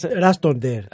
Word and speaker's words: Ράστοντερ: Πώς τον προφέρουμε. – Ναι. – Ράστοντερ, εντάξει, ναι.